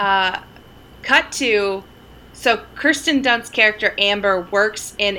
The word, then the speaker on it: Kirsten: So this dude, right, is hitting on Kristen Dunst. Kirsten Dunst. I always say it wrong.